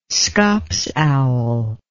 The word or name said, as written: scops owl